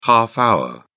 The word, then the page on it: half-hour: British English